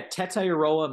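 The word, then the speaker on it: Tetairoa: Is it Tetayor? How do you say it? – Yeah, Tetayoroa McMillan, but okay.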